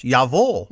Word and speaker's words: jawohl: That's why in German, the word for yes, you know, J-A-W-O-H-L, is pronounced Yavol.